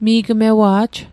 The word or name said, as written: Mi'gmewa'j